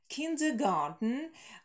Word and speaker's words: Kindergarten: Kindergarten произносится на немецкий манер, слово немецкого происхождения, и здесь именно немецкое слово kinder, дети, и garden, сад. Kindergarten, не garden, немецкое, английское сад, garden, а именно garden, garden, kindergarten.